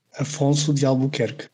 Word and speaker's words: Afonso de Albuquerque: After so badly butchering Alfonso's name, I asked my Portuguese friend for the correct pronunciation. So here it is. – Afonso de Albuquerque.